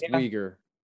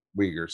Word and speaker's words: Uyghur: I believe the whole Uyghur thing is still happening. We're just not hearing it. – It's pronounced Uyghur. – Uyghur, sorry.